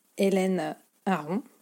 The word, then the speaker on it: Elaine Aron: est tiré des travaux de la psychologue américaine Hélène Aron, alors je le prononce à la française. Hein. Donc ça s'écrit E-L-A-I-N-E. Euh, e. Son nom de famille c'est A-R-O-N.